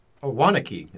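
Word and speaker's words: Waunakee: Wanakee is how you'd say it.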